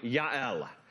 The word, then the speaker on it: Yael: There's no J in the Hebrew alphabet. Her name is spelled and pronounced with a Y, Yael.